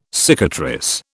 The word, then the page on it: cicatrice: British English